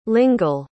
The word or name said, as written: lingle